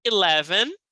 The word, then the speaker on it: eleven: Depois a gente tem eleven. E aí, ele tem um som, ele se escreve com E, mas tem um som de I.